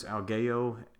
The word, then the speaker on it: Algeo: I do not know how to pronounce the author's last name. It's Matthew. I believe it's Algeo, A-L-G-E-O, Algeo, something like that.